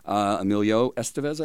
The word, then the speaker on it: Emilio Estevez: Emilio Estevez, I believe, is the correct pronunciation.